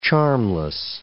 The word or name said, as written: charmless